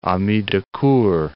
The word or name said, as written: ami de cour